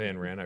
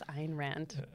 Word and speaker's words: Ayn Rand: I love Ayn Rand. I've read everything that she's written. – I believe it's Ayn Rand.